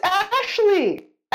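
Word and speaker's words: Ashleigh: I mean, her name is like not pronounced the way it's spelled, like bologna or lasagna. – It's Ashley. Ash but Lee, L-E-A, Lee.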